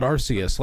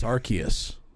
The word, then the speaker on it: Arceus: It's not Arceus like you've thought your entire life. It's Arceus. It's a hard K.